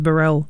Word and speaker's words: Burrell: Okay, that was Chitlins Con Carn by Kenny Burrell. Felix has told me the correct pronunciation is Burrell, so I'll be fixing that up from now on.